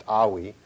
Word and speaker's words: Ai: This was performed after they defeated the city of Ai, as many people who speak English say, it's pronounced Awi, but it's just spelled A and I.